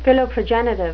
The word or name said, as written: philoprogenitive